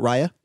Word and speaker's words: Raya: And they were like, yeah, we started this thing called Raya. I was like, yeah, I've heard of that. Raya? I'm sorry. Let's just call it Raya. How do you say it? R-A-Y-A.